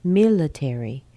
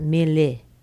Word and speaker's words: military: And mila, military. Military, l, l, l, not mille, mille, mille, military, military. Okay, next we have the sound s, s, written as an s plus a schwa, although it can be spelled with a c.